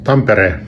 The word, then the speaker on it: Tampere: Tampere is pronounced Tampere.